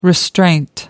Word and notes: restraint: American English

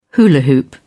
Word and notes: hula-hoop: British English

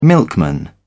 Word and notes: milkman: British English

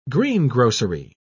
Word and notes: greengrocery: British English

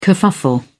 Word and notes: kerfuffle: British English

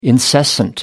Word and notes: incessant: American English

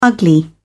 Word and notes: ugly: British English

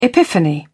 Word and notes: epiphany: British English

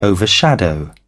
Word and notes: overshadow: British English

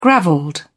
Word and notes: gravelled: British English

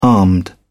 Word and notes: armed: British English

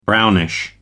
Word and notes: brownish: American English

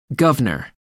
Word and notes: guvnor: American English

